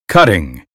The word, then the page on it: cutting: American English